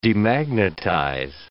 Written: demagnetize

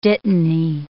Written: dittany

